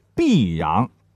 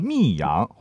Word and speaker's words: 泌阳: 泌阳啊，你不能读成密阳或者沁阳。泌阳的泌就是三点水旁边是一个必须的必，而沁呢是沁园春的沁，三点水旁边是一个心。这俩字的区别呢，就是一撇。我们曾经讲过沁阳和泌阳因为一撇之误而改变历史的故事。